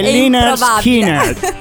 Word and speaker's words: Lynyrd Skynyrd: Skynard, facilissimo. Però mi dovete dire anche chi canta questa canzone. – Lynyrd Skynyrd, che la pronuncia, Skinner, la pronuncia vero è Lynyrd Skynyrd.